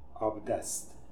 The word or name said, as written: Abdest